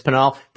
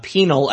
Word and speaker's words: Pinal: And yes, I know now that it's Pinal. President Trump pronounces it penal as well. And so yesterday I heard from more than a few Arizona residents of Pinal County who let me know that it's not pronounced penal. It's not a penal colony. It's Pinal County.